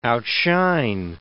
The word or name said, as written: outshine